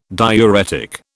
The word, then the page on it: diuretic: British English